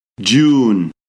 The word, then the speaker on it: June: You, June. You, June. Magic E. E is silent. U with an E at the end makes the sound U.